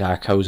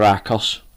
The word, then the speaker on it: Archos: and the Archos jukeboxes. I think it's Archos anyway, A-R-C-H-O-S. We'll pronounce it Archos, or Arcos. I prefer Archos somehow, it's better. Anyway, about the player.